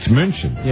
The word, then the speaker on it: München: And how do you say Munich in German? – Munich. – Wrong. Oh. It's München.